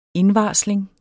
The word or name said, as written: indvarsling